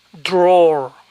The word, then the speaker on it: drawer: Tiene un nombre específico y se pronuncia drawer.